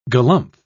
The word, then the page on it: galumph: British English